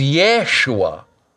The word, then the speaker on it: Yeshua: So Jesus' name, Jesus, okay, Joshua, Jeshua, is Yeshua, okay? But we have all these J sounds that come from the original Germanic English, okay? So Yehovah becomes Jehovah, and it was never intended to be pronounced at all, right?